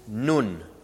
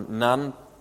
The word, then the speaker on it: Nun: And that ocean is called Nun, or Nun, depending on your pronunciation.